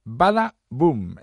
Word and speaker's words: Bada Boom: Baba, perdón, lo he dicho mal, ¿eh? Bada Boom es como se llama esta canción.